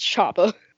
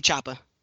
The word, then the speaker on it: Choppa: Chopper. You said Chopper. It's Chopper.